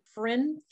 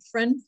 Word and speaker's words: Phryne: And it says, driving home late one night, Fryn Fisher, is that how you say her name? – I have no idea. She has a very strange name. – She does, and it's spelled P-H-R-Y-N-E. That's way too many consonants.